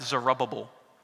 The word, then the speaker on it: Zerubbabel: That you pronounce that Zerubbabel in American English.